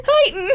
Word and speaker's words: Huyton: H-U-Y-T-O-N. Which is outside of Liverpool. – You think H-U-Y-T-O-N would be pronounced Houghton? – How would you say it? Heighton? – Heighton. – Heighton.